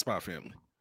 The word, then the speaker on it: Spy x Family: It's Spy X Family, but it's pronounced Spy Family.